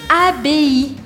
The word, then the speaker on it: abbaye: Porque vocês lembram que A com I eu falo E e I sozinho, eu falo I. E o E no final, eu não pronuncio. É por isso que então eu vou falar A, B, une abbaye.